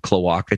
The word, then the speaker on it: cloaca: They might have cloaca too.